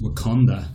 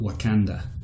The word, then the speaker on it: Wakanda: Yeah, and he pronounces Wakanda the same way I do, which is Wakanda, not Wakanda.